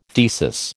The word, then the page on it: thesis: American English